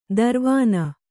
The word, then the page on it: ದರ್ವಾನ: darvāna